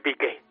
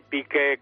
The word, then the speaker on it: Piqué: Todo suena igual, porque vamos a ver lo de Piqué, que tiene acento en la E.